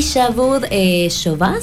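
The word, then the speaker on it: Shabooh Shoobah: Y Shavud Shoba se pronuncia.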